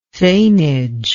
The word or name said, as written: thanage